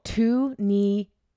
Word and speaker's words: Tunisia: Are you sure that's how you pronounce it? – No, I'm not. The only reason I pronounced it like that is because my dad used to go there for work and he said it like that. So I can assumed. I see? I'm just Googling Tunisia pronunciation. – Usually Google will. – Tunisia. – Tunisia. – So I was pretty close. – Tunisia. How do you spell that? – T-U-N-I-S-I-A.